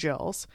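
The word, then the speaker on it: gill: And alcohol was measured in jacks and gills with a G, but I bet you it was said like Jill, because like the name Gillian with a G can be pronounced Gillian or Jillian, just depending. So alcohol was measured in jacks and jills.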